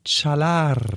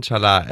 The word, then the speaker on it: Çalar: Hier ist äh, Tan Chalar. – Oh, wie ein Türke ausgesprochen von ja, da, ne? – Simon ja.